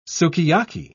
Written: sukiyaki

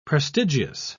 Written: prestigious

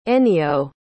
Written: ennio

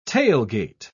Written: tailgate

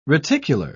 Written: reticular

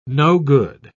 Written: no-good

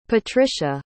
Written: patricia

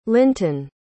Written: linton

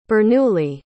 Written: Bernoulli

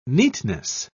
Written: neatness

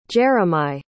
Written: jeremih

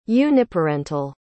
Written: uniparental

